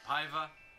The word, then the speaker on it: Paiva: Julie Adams, Richard Denny, Antonio Moreno, Nestor Pavia. Oh wait, Paiva. – Pivia. – There you go. – Paiva. If we're pronouncing it in the Spanish way.